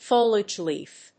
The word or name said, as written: foliage leaf